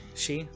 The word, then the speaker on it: Xi: But the fact that uh, that uh, how do you pronounce his name is Xi Xi Jinping from uh, China. – Uh, the president. Yeah. She.